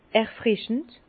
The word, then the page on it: erfrischend: Bas-Rhin; Herrlisheim